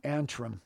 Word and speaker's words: Antrim: Atrium, is that how you say it? Atrium or Antrim Point? – Atrium? Antrim. – Antrim, Antrim.